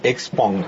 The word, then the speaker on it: Xpeng: Bueno, se pronuncia Xpeng.